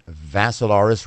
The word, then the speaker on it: Vassilaris: It's easy to use, And look for Dimitri with three I's, D-I-M-I-T-R-I. Last name is Vassilaris, rhymes with Asilaris, but you add a V to it to clean it up.